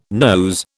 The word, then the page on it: nose: British English